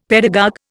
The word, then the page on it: pedagogue: Indian